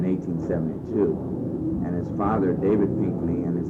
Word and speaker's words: Pinckney: Okay, the spelling is P-I-N-C-K-N-E-Y. Many times it's spelled, I think, even out at Pioneer Village, P-I-N-K-N-E-Y, so the C was left out.